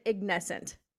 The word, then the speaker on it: Ignescent: Now, tell me, am I pronouncing it right? I pronounce it Ignescent. – Yeah, you're right. Perfect.